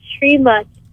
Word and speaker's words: Srimath: My name's not like Srinivas, it's Srimath.